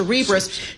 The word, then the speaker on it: Cerebras: How do you say your name? Like Cerebral. – Yes. – So Cerebras shares soaring.